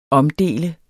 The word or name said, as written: omdele